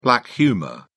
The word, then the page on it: black humour: British English